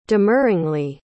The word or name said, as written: demurringly